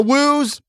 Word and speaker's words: Wu: Like the Woos driving Ferraris, and my guy, Dr. Wu, right? His entire family. Dr. Wu. – Uh-oh. – Dr. Wu. – It's pronounced Woo!